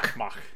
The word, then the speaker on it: Ma'ake: In the subtitles, it has an apostrophe in there, but the way they pronounce it in the show is just like all one thing. – Yeah. – Ma'ake.